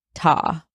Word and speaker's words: Ta: I will refer to him as Ta, rhymes with saw, which came from one of the sinister growling sounds he made.